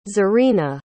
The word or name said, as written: zarina